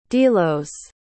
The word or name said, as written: delos